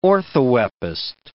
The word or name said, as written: orthoepist